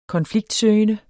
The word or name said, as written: konfliktsøgende